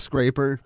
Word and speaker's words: Scraper: What about Krosan Cloud Scrapper? Did I say that right? – I think it's Scraper.